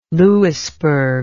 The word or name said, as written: louisburg